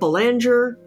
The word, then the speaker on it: Belanger: If it makes you feel any better, half my family pronounces it Belanger. – Belanger. – And most people are like, is that Belanger? Like, what is that? It really depends on like where you're from.